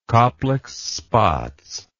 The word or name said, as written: kopliks spots